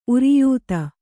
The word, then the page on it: ಉರಿಯೂತ: uriyūta; Kannada